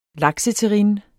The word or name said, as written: lakseterrine